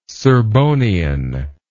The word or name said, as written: serbonian